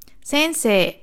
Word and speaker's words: Sensei: Das heißt, es ist ein langes E, nicht Sei, sondern Se in diesem Fall. Sensei.